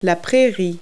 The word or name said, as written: prairie